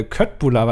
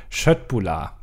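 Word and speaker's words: köttbullar: ähm, hat sich beschwert darüber, dass ich äh, Köttbula beim letzten Mal falsch ausgesprochen habe, denn es heißt Schöttbula.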